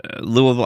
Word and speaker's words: Louisville: Louisville. I about said Louisiana. Louisville, Kentucky. – Louisville. – You got to say it right. Louisville. – Louisville.